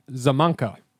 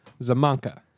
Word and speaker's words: Szamanka: I'm going to play Szamanka. – What? – Szamanka. – Szamanka. – Yes. S-Z-A-M-A-N-K-A. Szamanka. – Spell that again. – S-Z-A-M-A-N-K-A.